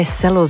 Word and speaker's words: cello: Maar daarom mocht je je vragen stellen waarom wij cello zeggen. Omdat het zo moet. Omdat het... Dat is eigenlijk de enige correcte uitspraak.